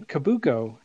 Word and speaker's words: Cabuco: Today, we have a very special guest, Daniel Cabuco.